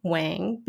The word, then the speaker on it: Wang: Like, so for me, I would be like, I'm gonna pronounce it Wang because that's how it's spelled, and that's how people will know the connection of like how to spell my last name and how to say it.